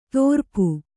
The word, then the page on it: ತೋರ್ಪು: kannada